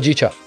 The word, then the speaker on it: Hojicha: Hojicha, is that how you pronounce it? – Hojicha. – Hojicha? – Yeah.